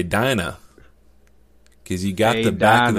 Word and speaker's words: Dinah: You just taught me, Bryant, that I can actually pronounce it Dina instead of Dinah.